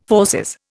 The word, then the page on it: fauces: Indian